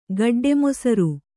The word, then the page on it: ಗಡ್ಡೆಮೊಸರು: gaḍḍe mosaru